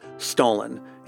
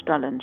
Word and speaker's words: Stollen: So far, you've heard me pronounce it a couple times as Stollen, and that's because I'm not going to embarrass myself with a failed attempt at affecting a German accent. For an authentic German pronunciation, I'll leave it to Louisa.